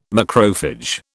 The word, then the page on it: macrophage: British English